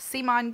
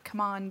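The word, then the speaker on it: CMON: The first one we're going to talk about is Modern Art, which is by Simon Games, or I guess they're pronouncing it Come On Games.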